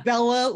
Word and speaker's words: Bela: I'm always like, it's Bela Lugosi, like, you know, Beauty and the Beast, Bella, it's not Bela Lugosi. It's Bela.